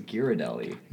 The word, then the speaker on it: Ghirardelli: Giardelli? – I think it's Ghirardelli. – No, it's G.